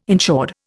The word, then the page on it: insured: Indian